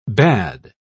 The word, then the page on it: bad: American English